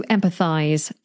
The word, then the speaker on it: empathize: To empathize. In American English, this is spelt with a Z. In British English, this is spelt with an S, but still pronounced like a Z.